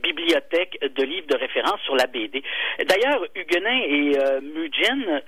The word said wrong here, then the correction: Huggins and Muggins: là, j'arrive pas à prononcer. – Huggins and Muggins. – Ouais, Muggins.